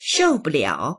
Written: shou4buliao3

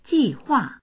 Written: ji4hua4

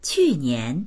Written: qu4nian2

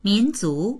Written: min2zu2

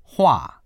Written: hua4

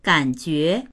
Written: gan3jue2

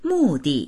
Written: mu4di4

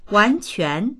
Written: wan2quan2

